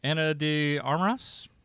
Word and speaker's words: Ana de Armas: Uh, how do you how do you pronounce the name? Ana de Armas. Sure. Yes.